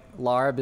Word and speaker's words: larb: But the reason like larb is spelled that way is because if you say it with like a English accent, like lap, like lag.